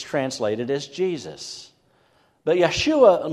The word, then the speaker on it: Yeshua: So when the angel said the name that we have in the text, Jesus, it would have been pronounced, because there's no J sound in Hebrew, so it's Yeshua.